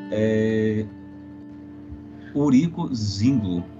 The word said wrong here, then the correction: Zwingli: Ah, Zwingli.